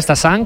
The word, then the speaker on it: Stassin: A pronunciarlo bien, Stassin, como nos han apuntado, y Félix Correia, como dice José, con muy buena pronunciación portuguesa.